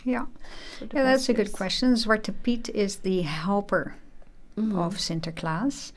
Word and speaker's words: Zwarte Piet: and correct me please if I pronounce it wrong, but in Dutch is Zwarte Piet. – Yeah, very good. Yeah. Zwarte Piet.